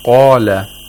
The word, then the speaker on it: Qala: The word Qala has a Qaf, which has to be pronounced with Tafkhim, with thickness.